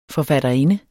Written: forfatterinde

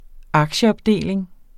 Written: aktieopdeling

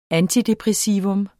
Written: antidepressivum

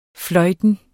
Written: fløjten

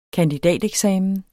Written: kandidateksamen

